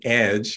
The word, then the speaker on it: EDJE: So the Education Deans for Justice and Equity, EDJE, we pronounce it EDGE because we like to say we're on the edge.